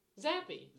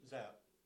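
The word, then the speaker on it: Zappe: It's Zapp. In college, it was Zapp, and I looked at the pronunciation the other day. It's Zapp.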